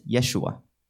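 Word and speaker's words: Yeshua (ישוע): And the Hebrew word is Yeshua. – 是拯救的意思。 – And this means to save. – 这个耶稣啊，在我们这个基督徒平时用的语言里面，有没有哪另外一个词跟这个听起来很像？ – And in our Christian vocabulary, is there a similar word to this word Yeshua? – 有人说是耶稣的名字。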